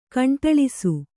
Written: ಗುಂಡಾಳಿಸು

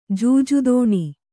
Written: ಜೂಜುದೋಣಿ

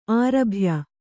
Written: ಆರಭ್ಯ

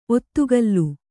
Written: ಒತ್ತುಗಲ್ಲು

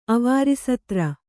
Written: ಅವಾರಿಸತ್ರ

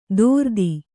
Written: ದೋರ್ದಿ

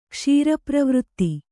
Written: ಕ್ಷೀರಪ್ರವೃತ್ತಿ

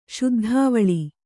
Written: ಶುದ್ಧಾವಳಿ

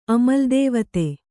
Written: ಅಮಲ್‍ದೇವತೆ

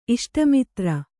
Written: ಇಷ್ಟಮಿತ್ರ

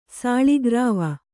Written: ಸಾಳಿಗ್ರಾವ

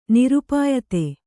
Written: ನಿರುಪಾಯತೆ

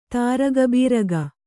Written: ತಾರಗಬೀರಗ

